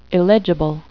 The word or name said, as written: illegible